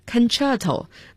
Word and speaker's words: concerto: Concerto, 大家注意一下 C 字母的发音 Concerto 代表协奏曲，应该是很多种乐器在一起演奏吧。